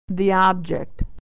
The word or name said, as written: the object